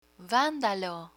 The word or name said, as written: vandalo